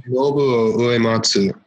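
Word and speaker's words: Nobuo Uematsu: Nobu Uematsu.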